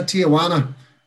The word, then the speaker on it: Tijuana: Next one is from Simon Finn, and he said Sean Bannon moving to Tijuana. Did I say it right? – Yeah, Tijuana. I'm nearly sure that is in South America or Mexico or something like that.